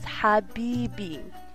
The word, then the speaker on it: Habibi: This episode's Arabic word of the day is one that you've definitely heard before, Habibi. So to properly pronounce this word, you need to make a very hard H sound, like ha, ha, like you have eaten something really spicy. So it's Habibi, which means my love or my friend.